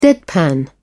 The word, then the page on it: deadpan: British English